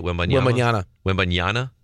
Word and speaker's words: Wembanyama: Wembanyama. – It's Y-A-N-A?